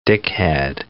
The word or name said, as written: dickhead